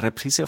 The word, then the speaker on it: reprise: Wie spricht man eine Reprise auf Englisch aus? – Reprise.